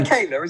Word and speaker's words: Michaela: Michaela, isn't it? Yeah, number 20 is Michaela Coel from I May Destroy You.